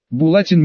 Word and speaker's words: Булатин: Булатин Михалин, ударение на «и».